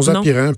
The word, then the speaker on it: Zapirain: Non, non, Zapirin.